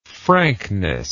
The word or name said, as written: frankness